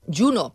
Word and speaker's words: Juno: Beatriz, què és Juno? Juno, perdó, Juno, perquè és la... Saps? – Sí, sí, ens ho pronunciem de totes les maneres i no passa res.